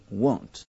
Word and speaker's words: won't: 那么 will not 可以略写为 won't，注意读音 won't。